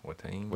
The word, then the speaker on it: Watain: So we went to Kansas City to go see Destroyer 666 and Watain. Am I saying that right? – Watain.